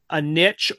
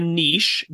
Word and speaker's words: niche: Well, without commenting on the content of what these guys talk about, you know, many of them have carved out a niche or a niche, depending on your preferred pronunciation of that word.